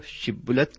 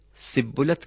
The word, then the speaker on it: شبلت: تو وہ شبلت کے بجائے سبلت کہتا تھا اور اس کے سبب سے پھر وہ قتل کر دیا جاتا تھا اس لفظ کو صحیح تلفظ کے ساتھ نہ بولنے کی وجہ یہ تھی کہ افرائیمیوں کی زبان میں سین حرف تو تھا لیکن شین حرف نہیں تھا